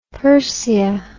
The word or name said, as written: persea